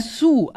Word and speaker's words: Sioux: Aber es wird Sioux, einfach Sioux, wie französisch unten oder genau.